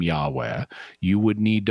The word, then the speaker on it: Yahweh: One of them would mean that if you say just the name of God, the four letters, the so-called tetragrammaton, which in English is commonly pronounced Yahweh,